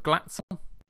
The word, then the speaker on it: Glatzel: Is it Robert Glatzel? – Yeah, that'll be fine. You could go a little bit more on the A, but I'm pretty sure it'll be anglicised like most players are when they come over to the UK.